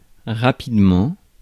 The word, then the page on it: shortly: American English; /ʃɔːrtli/